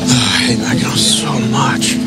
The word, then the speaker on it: Mackinac: Oh, no, Mackinac Island. – Mackinac Island. – First of all, yeah. – Yeah. It's spelled, why do they spell it Mackinac when it's Mackinac?